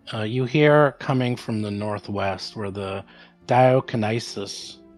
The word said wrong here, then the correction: Deinonychus: Is that how you pronounce it? Whatever. – Deinonychus, Deinonychus dinosaurs are kind of moaning and making some sounds.